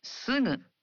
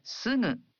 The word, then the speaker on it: すぐ: In Japanese, you pronounce gu in the initial syllables of words as a voiced gu, like ga, gi, gu, ge, go. But for other syllables, such as sugu, you pronounce the gu sound as a nasal.